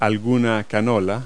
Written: canola

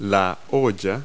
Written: pot

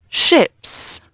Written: ships

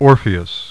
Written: Orpheus

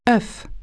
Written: egg

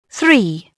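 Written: three

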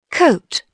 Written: coat

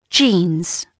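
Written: jeans

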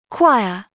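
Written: choir

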